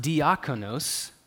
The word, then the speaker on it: diakonos: If you define it with its prefix and suffix, dia and konos, it's pronounced diakonos. See, that's how we get deacon.